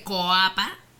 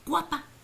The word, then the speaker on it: Coapa: Porque se escribe Coapa, C-O-A-P-A, pero dice, se dice Coapa, Coapa. – Como guapa. – Como guapa, pero con C, porque eso es lo que significa. – Porque, y aparte, la OA se transforma y se pronuncia como en guapa. Una U.